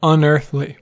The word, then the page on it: unearthly: /ʌnˈəːθ.li/; /ʌnˈɝθ.li/